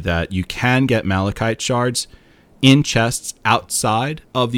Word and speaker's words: Malachite: Oh, and I'm telling Tales of Tamriel, that's how you pronounce it as well. It's Malachite.